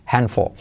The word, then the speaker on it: handful: No se pronuncia la D, handful. Letra clave L al final, casi no se pronuncia, handful.